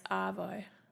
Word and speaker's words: arvo: We don't really pronounce the R. It's Arvo.